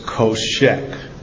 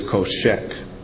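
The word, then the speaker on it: koshek (חושך): It's spelled C-H-O-S-H-E-K. It's pronounced koshek. Darkness in Hebrew is koshek.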